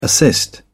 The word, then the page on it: assist: British English